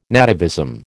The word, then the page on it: nativism: American English